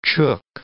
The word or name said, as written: chook